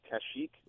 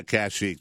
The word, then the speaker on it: Kashyyyk: I think it's pronounced Kashyyyk. – Yeah, Kashyyyk.